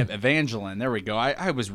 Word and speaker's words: Evangeline: Evangeline? – Yeah, sounds good.